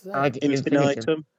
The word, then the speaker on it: Ad Infinitum: Ad Infinitum? Infinitum? How do I say it? I've never known Ad Infinitum.